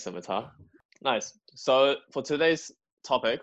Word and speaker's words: scimitar: A scimitar.